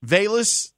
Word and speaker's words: Velus: Velus Jones.